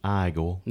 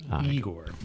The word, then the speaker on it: Igor: Which, if you, there was a bit of trivia on that, where I guess Marty Feldman's character, Igor. – Nope. Now it's pronounced Igor.